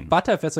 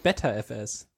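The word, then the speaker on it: Btrfs: Genauso wie wie ButterFS ist ja auch ButterFS und nicht BetterFS.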